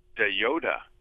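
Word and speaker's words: de Joode: how did you say the name? Wilbert? – Wilbert DeYoda. – Oh, DeYoda. – Wilbert DeYoda. – Yeah. Not spelled like the Star Wars character at all.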